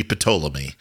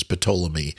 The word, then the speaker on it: Ptolemy: In Greek, it would be Ptolemy. It would you pronounce the P. – Every consonant is pronounced in Greek.